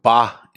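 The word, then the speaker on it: ba (八): It is pronounced ba in Chinese.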